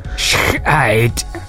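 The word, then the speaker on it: Schijt: No, it's S-C-H-I-J-T. So, it, of course, you pronounce it differently, but it's sh- and an eight. – Sh-eight.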